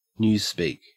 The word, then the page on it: newspeak: Australian English